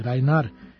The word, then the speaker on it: Reinhardt: Rođen u romskom kampu u Belgiji, Django Reinhardt ili Rainar, kako se još izgovara njegovo prezime,